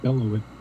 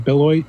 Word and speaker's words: Beloit: And the affiliate for High Triple A is in, I don't even, correct me if I'm pronouncing this wrong, but Belleville, Wisconsin. – I think it's Beloit. – Beloit? – Beloit, I mean.